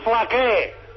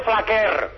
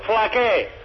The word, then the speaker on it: Flaquer: Por cierto, Carreño, ya que vienes aquí, se dice Lluís Flaqué. La R es muda, no se dice Flaquer. Ya que vienes aquí a la sede de la Alianza Rebelde, pues ya te indico yo, Flaqué. Flaquer no se dice, la R es muda, catalán las R son mudas.